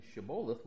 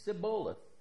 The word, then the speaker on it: Shibboleth: The problem was that the closest the Ephraimite could come to saying Shibboleth was Sibboleth.